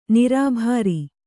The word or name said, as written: ನಿರಭ್ರ